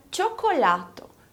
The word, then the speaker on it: cioccolato: Esse co vai ser pronunciado igualzinho como em português. Olha para a palavra chocolate, que é a própria tradução de cioccolato. Esse co do chocolate está sendo falado da mesma forma como em cioccolato.